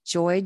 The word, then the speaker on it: Joye: It's Mary, M-A-R-Y, Joy, J-O-Y-E.